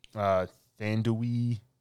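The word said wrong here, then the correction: Thandie: Thandi Newton.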